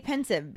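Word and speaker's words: Pensieve: I say pensive because it's the combination of the word pensive, spelled P-E-N-S-I-V-E, and a sieve, which is spelled S-I-E-V-E. So I think it's still supposed to be pronounced pensive and just kind of punny in its spelling.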